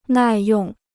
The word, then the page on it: 耐用: nài yòng